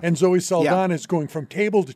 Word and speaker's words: Saldana: And by the way, it's Saldana. – Yes, well, Saldana. So let's be sure we get that right. – Saldana.